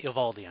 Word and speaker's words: Eovaldi: They say Eovaldi on TV. – Eovaldi is, I think you're right, they make that one syllable.